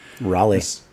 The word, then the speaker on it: Raleigh: Raleigh.